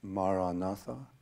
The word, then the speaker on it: Maranatha: The word I suggest is Maranatha. If you choose that word, say it as four syllables: Maranatha. Ma-ra-na-tha.